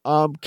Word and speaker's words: Sanrio: Sanrio.